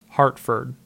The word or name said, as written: Hartford